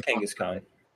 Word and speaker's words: Kangaskhan: How do you say the kangaroo one? Kangaskhan? – Oh, yeah. – How do you say it? I was told I said it wrong. – It was Kangaskhan.